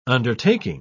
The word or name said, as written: undertaking